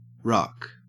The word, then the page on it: ruck: Australian English